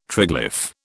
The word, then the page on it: triglyph: British English